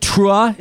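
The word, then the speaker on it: T'ruah: Rabbi Jill Jacobs, executive director of, do you pronounce it Trua? Is that how you say it? – You got it exactly right.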